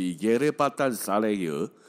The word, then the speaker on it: Yerebatan Sarayı: 其中比较大的这个地下水宫殿呢，土耳其话 Y E 开头的 Y E R E B A T A N 哈，这个开头的，它像它的土耳其文的意思叫做沉默的宫殿，那个念起来大概都是耶勒巴丹萨雷尔，好的。